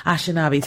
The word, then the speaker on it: Anishinaabe: These stories, if they hear them, if they see them, if they know what it takes for the Asan, Asan, how do you say their tribe? Anishinaabe. Thank you. – Anishinaabe.